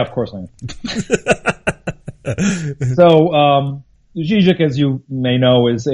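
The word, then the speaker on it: Žižek: You're the managing editor and co-founder of the International Journal of Zizek. Am I saying that correctly? – It's Zizek. It's a sort of soft Z. Okay. It's got the little carrot above it.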